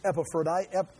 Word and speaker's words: Epaphroditus: Here, after he talks about Timothy coming, Epaphroditus. If I say E, I'm going to get it right. Epaphroditus. If I say Ep, now I get my syllables mixed up.